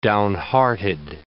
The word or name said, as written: downhearted